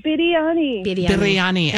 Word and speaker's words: Biryani: I didn't say it right. Say it again. – That's all right. Biryani.